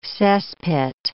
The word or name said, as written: cesspit